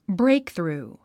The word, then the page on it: breakthrough: bréikθrùː; ブレイクスルゥ